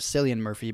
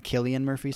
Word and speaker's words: Cillian: He was talking about Cillian Murphy and how he, which I thought it was Cillian Murphy, but Christopher Nolan said Cillian Murphy, so I believe Christopher Nolan's pronunciation.